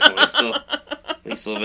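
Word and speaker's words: Liesl: I think it's Liesel. I know a girl named Liesel. – Oh, really? – Yeah. – Okay, well, maybe it is Liesel Van Helsing.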